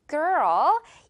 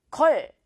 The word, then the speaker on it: girl: 이거 발음할 때 그냥 걸 아니죠. 뭔가 한 글자 같은 걸이 아니라 지금 여기에 삽 발음이 나와야 되다 보니까 girl. 이렇게 약간 두 글자라고 내가 생각을 하면서 가면 훨씬 더 진짜 발음에 가까워져요.